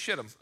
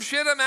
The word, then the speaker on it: Shittim: Sent two men secretly from, how do you say that word? I looked it up twice. It's Shittim, okay? I double checked it before I said it publicly. Sent two men secretly from Shittim as spies.